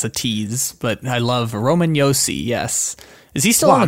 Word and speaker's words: Roman Josi: Is Roman Josi? – Roman Josi.